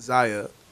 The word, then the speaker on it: Zaya: Z- did I say that right? Zaya? Yeah, Zaya? Zaya? No idea. Zaya. – Zaya. That's what I'm saying.